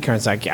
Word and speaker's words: Krasinski: Krasinski. I don't know. I'm not pronouncing that right, but something like that. – Yeah, that's right.